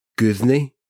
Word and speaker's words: Guðni: I believe it's pronounced Guthney. – Flawless. Yeah, perfect. – Welcome, Guthney.